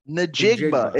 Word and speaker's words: Njigba: Njigba.